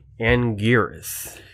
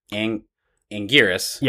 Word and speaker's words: Anguirus: Anguirus. Or Anguirus. Or Anguinus? – I don't like that one. – I hate Anguinus, but that's canon in a movie of how they pronounce it.